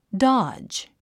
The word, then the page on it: dodge: dάdʒ; ダジ